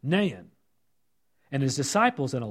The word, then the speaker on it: Nain: Walk with me, as now we're walking into the city of Nain, or some pronunciations are just Nain.